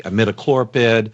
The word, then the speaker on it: imidacloprid: imidacloprid that I always mispronounce.